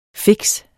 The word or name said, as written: fix